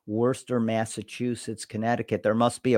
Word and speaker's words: Worcester: Coming in at number eight is, you know how to pronounce that? – Worcester. – That's right. Worcester, Massachusetts.